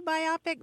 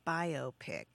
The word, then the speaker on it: biopic: Well, Kate, what we can tell you is that some people pronounce it the way you do, biopic, but the vast majority of folks say biopic. And that's because it is indeed a biographical film. It's a combination of the bio from biographical and pic as in picture.